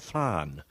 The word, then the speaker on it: flan: And then for dessert, it'll be traditional flan.